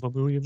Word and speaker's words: Dormammu: Dormammu.